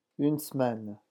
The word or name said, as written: une semaine